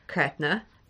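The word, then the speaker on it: Creidhne: I'm just using Ella Young's pronunciation yeah. – there. It is Govnu, Lochta, Kretna.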